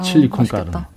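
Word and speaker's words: chili con carne: 칠리콘 까르네라고요. 이거 멕시칸 발음 맞나요? – 어, 칠리콘 까르네.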